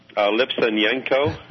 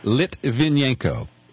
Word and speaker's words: Litvinenko: Lipsenyenko? – Litvinenko.